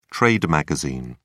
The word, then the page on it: trade magazine: British English